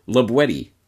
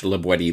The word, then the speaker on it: La Boétie: Properly pronounced not, as might be thought, La Boétie, but rather La Boétie, with the hard T, as it was pronounced in the Périgord dialect of the region in which La Boétie lived.